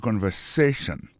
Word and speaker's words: conversation: Listen to the conversation. Coutez conversation. Mot conversation, accent tombé sous avant-dernier syllabe là, you know? Listen to the conversation.